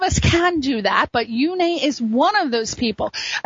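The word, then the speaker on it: Uné: So we're going to talk about Yune. Yune, Yune, Yune. It's spelled U-N-E with a little slash over the E, and Yune is he is a self-taught singer-songwriter, which I think is absolutely incredible, amazing.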